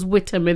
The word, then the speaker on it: Witham: It was Witham, in fact. We pronounce the T of Witham.